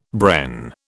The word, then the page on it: Bren: British English